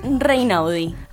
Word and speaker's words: Reynaudi: Alma Reinau. ¿Lo dije bien? – Reinaudi.